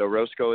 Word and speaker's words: Orozco: My username is Josh_Orozco_, and Orozco is spelled O-R-O, Z like zebra, C like Charlie, O.